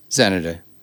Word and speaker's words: Xanadu: Xanadu. – Right. Ah, that's right. The X is silent. – It's not silent. It's pronounced as a Z, like zenith.